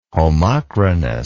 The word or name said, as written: homochronous